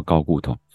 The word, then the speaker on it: 睾固酮: 睾固酮应该很生气吧？说一直被念错名字。 – 好的，我们再一次。好，睾固睾固丸对我们这么重要，特别是对我们这种重训的人很重要来说，那睾固酮是什么？ – 睾固酮也可以念成高固酮。是，那我们就念标准的好了，就叫高固酮。